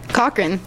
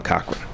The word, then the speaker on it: Cochran: Cochran. Oh, no. – As in Tom Cochran.